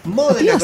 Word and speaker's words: Modena: Modena, con acento en la O.